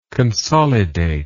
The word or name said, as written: consolidatory